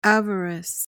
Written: avarice

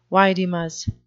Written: vidimus

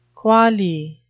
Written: quale

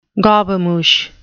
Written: gobemouche

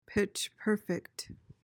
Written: pitch-perfect

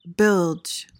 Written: bilge

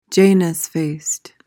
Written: janus-faced

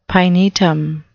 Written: pinetum

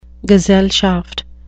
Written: gesellschaft

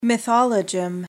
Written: mythologem

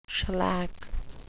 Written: shellac

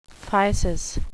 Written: physis

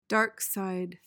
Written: dark side

